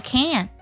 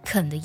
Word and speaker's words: can: 首先，我们要特别注意这里 how far you can go，c a n 这个情态动词呢，我们在读的时候不要读作 can。在我们的肯定句当中，通常它会弱读成肯的音，how far you can go，而不是 how far you can go，这样听起来就会特别像我们的否定式了。